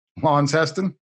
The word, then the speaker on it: Launceston: And my guest serves as pastor of the Free Reformed Church of Launceston, or how do you say that? – Launceston, in Australia.